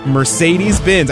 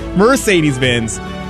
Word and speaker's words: Mercedes-Benz: Mercedes-Benz, I said it right this time, Rudy. Rudy's giving me the eye. A brand new Mercedes-Benz. Mercedes. Oh, I said it wrong again. Mercedes-Benz.